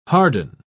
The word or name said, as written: harden